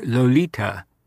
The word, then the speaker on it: Lolita: For the big picnic on Ada's 12th birthday and Ida's 42nd jour de fête, the child was permitted to wear her Lolita, thus dubbed after the little Andalusian gypsy of that name in Osberg's novel, and pronounced, incidentally, with a Spanish T, not a thick English one,